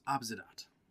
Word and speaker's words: Abzedat: His name is Abzedat. – Abzedat? – Mm-hmm. It's spelled just like it sounds.